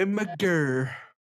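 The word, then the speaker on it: Imgur: Eu nem sei como é que fala isso. É Imager? É Imager. – Imager, é isso mesmo.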